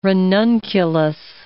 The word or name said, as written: ranunculus